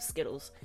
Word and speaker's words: Zkittlez: All right, let's get into Skittles. So it's not written as Skittles. It's spelled Z K I T T L E Z, and it is an indica.